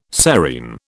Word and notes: serein: British English

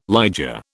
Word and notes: liger: British English

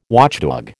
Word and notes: watchdog: American English

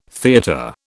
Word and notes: theatre: British English

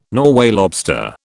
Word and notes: Norway lobster: British English

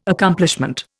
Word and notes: Accomplishment: Indian

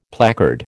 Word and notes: placard: American English

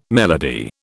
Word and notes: melody: British English

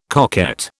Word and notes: coquette: British English

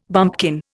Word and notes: bumpkin: Indian

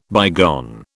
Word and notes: bygone: British English

